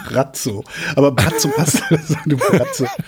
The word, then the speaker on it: Braco: Tatsächlich, Croatian Pronunciation Brazzo.